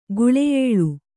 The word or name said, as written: ಗುಳೆಯೇಳು